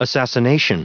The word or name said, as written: assassination